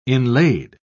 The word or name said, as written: inlaid